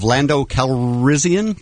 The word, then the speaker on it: Lando Calrissian: I have a clip from Star Wars Episode 2, and this is the clip of Lando Calrissian.